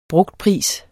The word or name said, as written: BRUGTPRIS